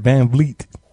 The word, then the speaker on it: Van Vliet: I've actually heard broadcasters, when they say his name, they really stress the Vliet. Vliet. – Yeah. – You got to say it. Because I would say Fleet in a minute, but it's Fred Van Vliet.